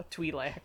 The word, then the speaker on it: Twi'lek: Species, she's a Tweakle? – Oh, okay. – Tweelik? – She's a Tweakle. – Tweelik? – A Twi'lek. – How do you say that? Twi'lek. Twi'lek.